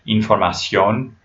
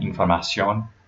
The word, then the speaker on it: información: So this could be something like información, like the O just before the N sound, información, or lápiz, lápiz, there's an accent on the A in the first syllable.